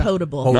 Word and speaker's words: non-potable: They're going to be using non-potable? Is that how you say it? – Non-potable. – Non-potable water.